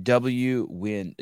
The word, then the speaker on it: Nguyen: And you Nguyen, you pronounce it W-Win, W-I-N. – That's correct.